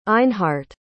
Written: einhardt

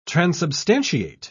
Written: transubstantiate

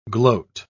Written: gloat

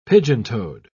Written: pigeon-toed

